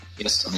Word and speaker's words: Эссен: Может, может быть, и Эссен Но вот у нас в, Чех, в Чехии ударение в основном на первый слог ставится. Так что давай, давайте использовать Эссен, если вам все согласны.